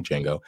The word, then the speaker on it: Django: The D is silent in the beginning, Django.